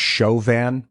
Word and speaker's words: Chauvin: He said that the name is pronounced Chauvin. – Allison C. says it's Chauvin, not Chauvin.